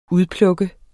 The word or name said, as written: udpluk